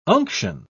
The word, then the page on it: unction: British English